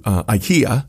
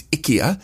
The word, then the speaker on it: IKEA: and we said we're going to IKEA. And they said, oh, no, that's not how you say it. – Okay. – It's IKEA.